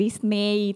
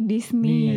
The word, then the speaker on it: Disney: Jadi kayak masih nggak tahu yang English dibacanya itu bukan Disney tapi Disney.